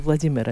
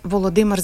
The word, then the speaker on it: Володимир: Дело в том, что если говорить правильно, то я должна была сказать: Володимир Зеленский сказал что? Не Владимир Зеленский сказал что, а Володимир Зеленский, если уже прям идеально правильно говорит.